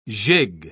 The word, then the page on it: gigue: British English